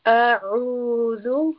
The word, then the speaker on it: أعوذ: So first we have the letter أعوذ, and here we have the letter عين, and عين is a letter of the throat. So we will pronounce it with a strangulation sound. أعوذ أعوذ أعوذ بالله من الشيطان.